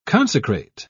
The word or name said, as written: consecrate